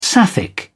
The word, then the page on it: sapphic: British English